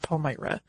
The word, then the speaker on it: Palmyra: I know they say Palmyra in the original episode. I listened to a bunch of different pronunciation guides. The overwhelming majority went with Palmyra.